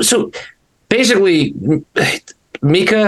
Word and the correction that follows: Micah Blake McCurdy: is that how you say it? Mika Blake McCurdy? – Micah Blake McCurdy.